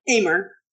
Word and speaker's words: Amer: Lindsay Ammer, am I saying that right? Ammer, but it's okay.